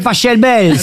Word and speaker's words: Pachelbel: Cannelloni, c'est Pachelbel. – S'il est allemand, ça se dit Pachelbel. – Exactement, ouais. Ça pourrait se dire éventuellement Pachelbel, mais on dit en tout cas, ici en France, le canon de Pachelbel.